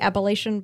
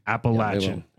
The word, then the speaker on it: Appalachian: In all fairness, it should be Appalachian, but it's Appalachian. – It is Appalachian.